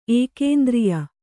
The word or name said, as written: ಏಕೇಂದ್ರಿಯ